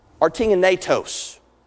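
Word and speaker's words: Artigonatos (ἀρτιγέννητος): Artigonatos. That's how you pronounce that.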